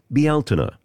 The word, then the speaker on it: Bealtaine: In Gaelic, it's pronounced Bealtaine.